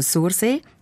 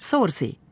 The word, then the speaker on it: Sursee: Also sie sagen Sursee, wie man schreibt, anstatt Sursee, wie man dort eben sagt. Und darum interessiert es ihn jetzt, bis wie weit von Sursee weg man eben noch Sursee sage.